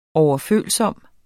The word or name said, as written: overfølsom